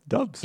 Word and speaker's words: Doubs: Still not sure that Dubs is how D-O-U-B-S is pronounced. That's like, it seems like Dubs like to me. – No. There's like a thing that clarifies how it's pronounced. – Oh, you mean a pronunciation guide? – Yeah, by Danny Kelly. Oh, they're okay. Yeah, it is pronounced Dubs.